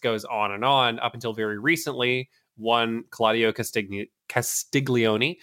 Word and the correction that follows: Castagnoli: I'm glad you pronounced it. Castagnoli. Sorry. Castiglione is something different.